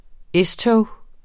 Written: S-tog

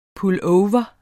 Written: pullover

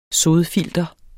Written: sodfilter

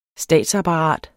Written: statsapparat

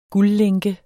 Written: guldlænke

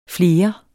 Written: flere

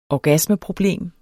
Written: orgasmeproblem

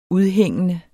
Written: udhængende

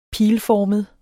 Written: pilformet

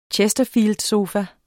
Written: chesterfieldsofa

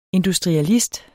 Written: industrialist

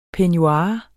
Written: peignoir

